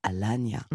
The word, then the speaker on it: Alagna: Une édition 2022 qui est parrainée par le ténor franco-italien Roberto Alagna, faut toujours le prononcer comme ça, qui a donné un concert d'ouverture le 17 janvier et qui fait honneur au son dans ce qu'il offre de plus intense.